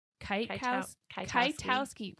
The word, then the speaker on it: Kwiatkowski: And so I'm sitting here today with Jordyn Kwiatkowski. Why do I just... – Don't pronounce the K.